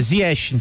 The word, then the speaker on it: Ziyech: A gente tem a pronúncia Ziyech, marroquina.